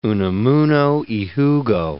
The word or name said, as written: Unamuno (y Jugo)